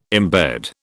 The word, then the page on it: embed: British English